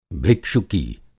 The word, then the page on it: भिक्षुकी: Hindi